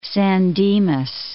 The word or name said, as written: San Dimas